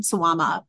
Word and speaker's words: Suwama: Yeah, they took out the ma and added in the, I'd probably pronounce it like as Mao, like on its own, but in the whole, it's the same pronunciation, Suwama, but it means like demon instead.